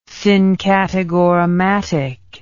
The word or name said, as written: syncategorematic